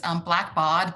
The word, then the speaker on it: Blackbaud: It's Blackbaud, BAUD.